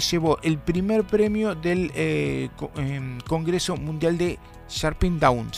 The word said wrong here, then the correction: Hampshire Down: ¿Se dice así? Usted que sí, conoce supongo. Razas, sí. Esta es la raza campeona, es una oveja, obviamente. – Hampshire Down. – Eso, usted lo pronuncia mejor que yo.